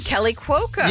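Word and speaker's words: Kaley Cuoco: Kelly Cuoco.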